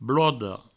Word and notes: bloda: blodo

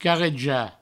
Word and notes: carrejar: provençal; carreja

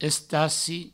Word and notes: estaci: provençal

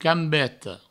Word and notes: cambeta: provençal; cambeto

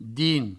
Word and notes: dins: Provençal

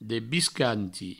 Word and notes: debiscanti: Provençal

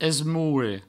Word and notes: esmoure: provençal

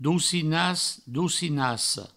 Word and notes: docinàs docinassa: Provençal; doucinas doucinasso

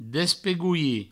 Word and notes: despegolhir: Provençal; despegoui

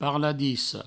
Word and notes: parladissa: parladisso

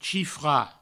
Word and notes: chifrar: chifra; provençal

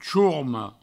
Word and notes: chorma: chourmo; provençal

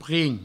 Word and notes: prim: provençal

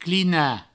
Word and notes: clinar: clina

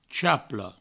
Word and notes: chaple: provençal

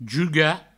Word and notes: jugar: juga; Provençal

